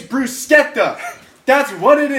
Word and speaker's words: bruschetta: It's bruschetta. C-H in Italian is K.